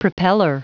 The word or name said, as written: propeller